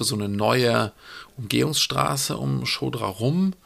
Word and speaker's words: Shkodra: Shkodra wird's ausgesprochen.